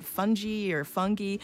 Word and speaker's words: fungi: So how do you pronounce F-U-N-G-I? – Fungi. – Fungi. Okay. Fungi. Well, I looked it up. Apparently, there are like three accepted pronunciations.